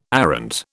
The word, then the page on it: arrant: British English